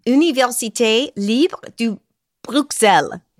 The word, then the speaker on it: Université Libre de Bruxelles: Université Libre de Bruxelles.